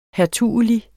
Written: hertugelig

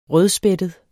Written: rødspættet

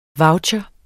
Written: voucher

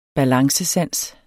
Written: balancesans

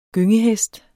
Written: gyngehest

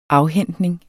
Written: afhentning

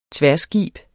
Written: tværskib

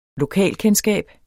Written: lokalkendskab

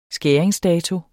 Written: skæringsdato

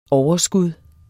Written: overskud

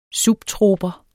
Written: subtroper

